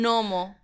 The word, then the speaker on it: gnomo: A ver, aquí dice que va un gnomo. Gnomo con G. ¿Vale? Gnomo. No pronunciamos la G, pero está ahí.